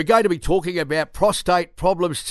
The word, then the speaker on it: prostate: Although I will be pronouncing it prostrate from time to time, and maybe you can text in and tell me when I say prostrate instead of prostate as well.